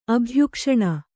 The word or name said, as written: ಅಭಯಘೋಷಣೆ